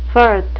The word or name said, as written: firth